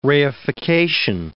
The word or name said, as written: reification